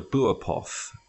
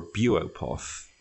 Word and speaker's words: Buopoth: Yeah, so this poor creature is called a buopoth, or a buopoth, depending on how you want to pronounce it.